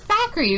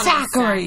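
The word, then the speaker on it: Thackery: His name was Thackeray. It's not like they pronounce it well. – Yeah, I never thought Zachary.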